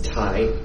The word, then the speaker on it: Thay: So, if you see T-H-A-Y, it's not Thay, it's Tai, so think Tai.